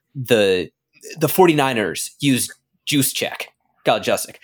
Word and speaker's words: Kyle Juszczyk: It's Kyle Juszczyk.